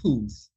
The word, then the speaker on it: Puth: Charlie Puth, the rich guy from New Jersey.